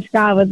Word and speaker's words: Scavas: Esattamente, Scavas con l'accento sulla A, esatto, l'hai detto benissimo Cosimo.